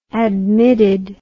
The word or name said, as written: admitted